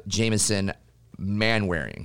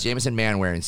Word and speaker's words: Manwaring: And we have Jamison Manwaring. Did I say that right? – You said it right.